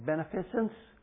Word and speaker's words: beneficence: And this love of benevolence expresses itself in, and I can't say this word very well, will you help me? Beneficence? Did I say it right? It's close enough.